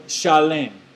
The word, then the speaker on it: Shalem: Salem in the Hebrew is the word Shalem. And most people pronounce that word shalom.